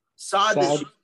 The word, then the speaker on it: Sadducees: But these people believe Sadducees.